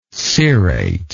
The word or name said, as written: cirrate